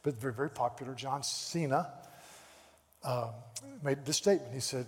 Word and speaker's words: Cena: Is it Cena? Cena? Is it Cena or Cena? Cena. Thank you, Cena.